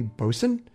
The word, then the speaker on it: bosun: I pronounced Bosoon wrong on the last show, and we got a really nice email from Rose, a really nice voicemail from Rose, who corrected my pronunciation, and she said it's actually bosun, and it's equivalent to a petty officer in the modern Navy.